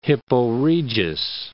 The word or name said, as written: Hippo Regius